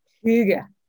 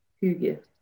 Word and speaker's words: Hygge: And am I even pronouncing that correctly? – The right way will be Hygge. – Hygge. – Hygge.